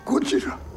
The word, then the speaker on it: Gojira: and he says, Gojira. – So apparently they decided to stick with the classic pronunciation, speaking of your stereotypes there.